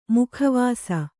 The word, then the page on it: ಮುಖವಾಸ: mukha vāsa